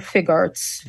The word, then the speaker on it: Figuarts: It's SHF Figuarts, right? – SHF Figuarts.